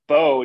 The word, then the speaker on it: Bau: You can't say how to pronounce people's names when your name's Bo and you spell it B-A-U. It's B-A-U. – That's how you spell Bo. – It's from a Canadian. – That's how you spell beau in French.